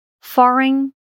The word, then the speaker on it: foreign: Seguindo com a nossa lista, a letra G não é pronunciada quando acontece a combinação GN no final de uma sílaba. Foreign, estrangeiro, sign, assinar, and align, alinhar.